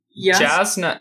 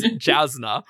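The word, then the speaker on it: Jasnah: Yasna. – This is a preview for the pronunciation podcast.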